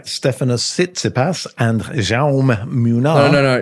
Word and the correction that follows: Jaume: No, it's pronounced Jaume. – Okay, Jaume. See, I speak Spanish, but I don't speak Catalan, and that's my excuse.